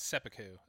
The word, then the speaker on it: seppuku: Well, it's definitely Chekhov's seppuku.